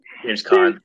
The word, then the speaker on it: James Caan: And so we get him to find his dad, Walter, who is played by freaking James Caan. Is that how you say it? – James Caan.